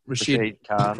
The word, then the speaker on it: Rashid Khan: Rashid Khan.